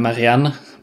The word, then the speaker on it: Marianne: Okay, Marianne ist, äh, oder Marianne, besser gesagt, ich habe das sehr deutsch ausgesprochen gerade, äh, ist Malerin und sie bekommt den Auftrag, Eloise zu malen.